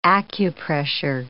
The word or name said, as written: acupressure